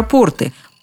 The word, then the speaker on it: порты: Но мы-то чаще всего говорим про порты, Порты – это именительный падеж. А дальше просто. Ударение на последний слог – портов, портам, портами, о портах.